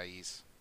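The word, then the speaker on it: Ys: is it Yis? Is that how you say it? Y-S? – Yeah, Yis.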